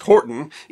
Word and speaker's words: Hoghton: The family first took the name Horton, spelled H-O-G-H-T-O-N, but pronounced Horton, in 1150.